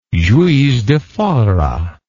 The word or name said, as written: Juiz de Fora